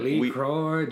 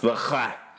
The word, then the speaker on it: LaCroix: When Lee Croix Jane. – He says Lee Croix, when we all know that it's actually pronounced Le Croix.